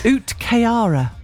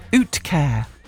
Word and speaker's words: uhtceare: Utkayara, or Utkair, is how you pronounce it. Uh-huh. And you spell it U-H-T-C-E-A-R-E.